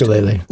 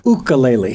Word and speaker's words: ukulele: It's actually pronounced ukulele. – Ukulele.